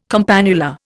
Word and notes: campanula: Indian

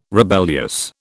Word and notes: rebellious: British English

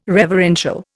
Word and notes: reverential: Indian

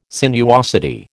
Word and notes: sinuosity: American English

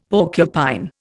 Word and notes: porcupine: Indian

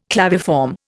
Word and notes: claviform: Indian

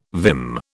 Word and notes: vim: British English

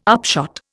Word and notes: upshot: Indian

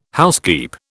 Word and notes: housekeep: British English

